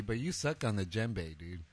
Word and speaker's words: djembe: It's a djembe, bro. Well, it's a djembe. Can you can you whatever? it, doesn't sound the way it's spelled, Jose.